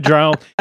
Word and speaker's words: drow: I say drow as well because that's how I've pronounced it since I started reading some certain series of books dozens of years ago. – It rhymes with cow.